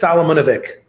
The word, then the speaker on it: Salamunovic: I have a hard time pronouncing your last name. Give it to me. It's like Hrvatsky sort of last name, right? You're Croatian? – Yeah, it's worse. It's Solomonovic. – Solomonovic. – Yeah, got it.